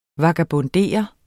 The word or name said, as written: vagabondere